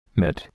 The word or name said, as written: mitt